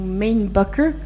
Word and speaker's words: Mainbocher: Euh, un autre exemple, c'est Mainbocher, ou Mainbucker, comme il faudrait le prononcer, euh, qui a longtemps été en fait le rédacteur en chef euh, et un correspondant du Vogue français.